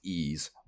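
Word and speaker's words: Ys: And the next is, this is the one worth talking about in my opinion, it is NEC's, it's just the letter Y-S, but it's pronounced E's,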